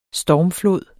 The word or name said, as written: stormflod